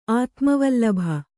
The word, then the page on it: ಆತ್ಮವಲ್ಲಭ: kannada